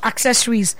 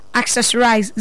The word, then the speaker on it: Accessorize: Our social media handle is Zini Accessorize, not accessories. Accessorize. Zini is spelled Z-E-N-E. When I say Z, I mean Z. Z-E-N-E Accessorize. So Accessorize, R-I-Z-E, again, not E-S. R-I-Z-E, as in Z.